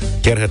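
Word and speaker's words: Kärcher: Povestește-ne o întâmplare amuzantă legată de pronunția greșită a numelui tău sau a cuiva apropiat, iar Kerher mi-a ieșit? Kerher ți-a ieșit perfect.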